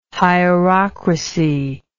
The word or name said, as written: hierocracy